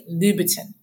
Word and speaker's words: Louboutin: So I'm very bad at these, but it's actually Louboutin. Louboutin, like that. Louboutin.